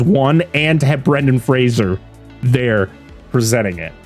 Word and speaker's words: Fraser: Fraser. – Rhymes with Razor. – Rhymes, yes.